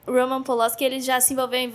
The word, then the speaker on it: Polanski: Polanski, não sei como pronunciar. – Polanski.